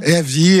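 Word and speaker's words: Eawy: Je ne sais pas si je le prononce bien. – Ehaoui, Ehaoui, Ehaoui.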